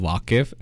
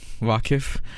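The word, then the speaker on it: Vaaqif: So far, you know, whatever I've played by the local train, it's called Vaqif and it's spelled as V-A-A-Q-I-F. I don't know what it means though, Vaqif.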